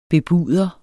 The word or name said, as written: bebuder